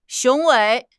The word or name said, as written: 雄伟